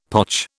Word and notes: potch: British English

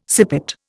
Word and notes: sippet: Indian

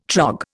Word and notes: trog: Indian

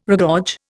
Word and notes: regorge: Indian

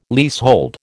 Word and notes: leasehold: American English

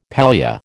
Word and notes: pallia: American English

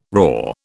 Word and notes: raw: British English

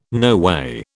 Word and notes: noway: British English

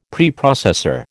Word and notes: Preprocessor: American English

